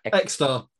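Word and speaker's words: Ecstar: Because is that, how do you pronounce it? – Exta.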